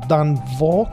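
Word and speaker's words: Danh Võ: Dan Vo, che mi diceva Fabio Cavallucci che si pronuncia tipo Joe, una roba del genere comunque.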